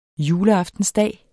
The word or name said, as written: juleaftensdag